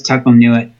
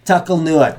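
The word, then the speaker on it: Tuc-el-Nuit: Is it Tiselnuit? – Tuckle. That's Tuckle-Newit. – Tuckle-Newit. That's how the locals pronounce it.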